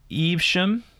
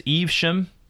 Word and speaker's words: Evesham: It's Evesham. I think I may have said Evesham or Evesham. Mm. But it's Evesham and Worcestershire.